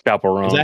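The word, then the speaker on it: Chappell Roan: Chapel Roan.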